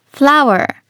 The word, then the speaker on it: flower: まず最初はこちら、花という意味の flower、そして小麦粉という意味の flour。 – 表記は花は FLOWER、小麦粉は FLOUR と違うんですが発音は全く一緒なんですね。